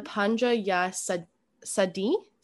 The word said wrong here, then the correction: Panga Ya Saidi: cave in Eastern Africa. Correct me now. – That's pretty good. So it's Panga Ya Saidi.